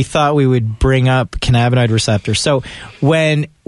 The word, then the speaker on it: cannabinoid: Is it cannabinoid? – Cannabinoid receptors.